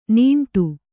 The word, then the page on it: ನೀಂಟು: nīṇṭu